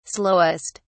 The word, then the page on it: slowest: British English